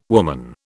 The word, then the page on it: woman: British English